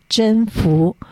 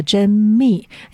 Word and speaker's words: 甄宓: 那在根据正史也记载哈，这个在官渡之战呢，曹操当时其实打赢之后呢，他其实虏获了一个非常出名的美人，那个美人的名字叫甄宓，或者也有发音叫甄宓，因为那个宓是一个秘密的秘，下面不要山啊。